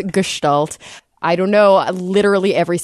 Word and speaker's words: Gestalt: But it is Gestalt. Hard G, S-H-E sound.